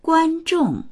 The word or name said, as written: guan1zhong4